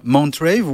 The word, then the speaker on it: Mt.Rave: Ah, déjà, avant qu'on se fasse flageller, est-ce qu'on prononce bien Montrave ou, ou autre chose Non, c'est exactement ça. C'est juste que l'écriture, c'est euh, écrit MT pour un rave, mais c'est bien Rave.